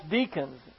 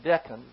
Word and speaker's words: decan: This is, for a long time, I pronounced that decan. That's what the other three constellations that accompany the main constellation, the sign of the zodiac there, but it's pronounced deacon.